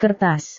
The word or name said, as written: kertas